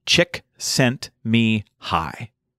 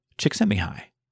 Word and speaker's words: Csikszentmihalyi: Csikszentmihalyi. Csikszentmihalyi.